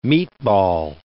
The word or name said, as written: meatball